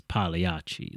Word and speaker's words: Pagliacci: Which I always said is Pagliacci or Pagliacci, and I do think in Italian it's Pagliacci. Like, I don't think you say the G Pagliacci. Sound. And so